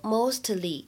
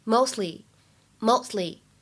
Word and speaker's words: mostly: 在整句话的朗读过程当中，我们注意一下开头这个单词，大家不要把它读成 mostly，因为这样子的话呢，就没有考虑到当中的不完全失去爆破的现象。应该把这个 t 只做了发音的口型，但是没有发出声音来。 mostly，mostly，后面的 get to 当中呢有一个完全失去爆破的现象，所以这里呢应该是 get to，get to。